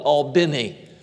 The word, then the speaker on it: Albany: It's spelled Albany, but if you get there, they call it Albany.